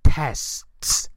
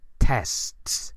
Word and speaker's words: tests: Y cuidado con la pronunciación del plural de test, porque a los españoles nos cuesta mucho esa concatenación de consonantes, es tests, tests, ¿de acuerdo? Bien, y pasamos a la última palabra, que sería el verbo prove, P-R-O-V-E, prove, que significa probar en el sentido de demostrar algo, ¿no?